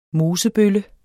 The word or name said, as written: mosebølle